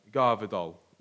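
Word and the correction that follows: Gvardiol: Is it? – Oh, Gavardiol.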